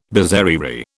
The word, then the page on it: bizarrerie: British English